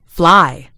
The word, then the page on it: fly: British English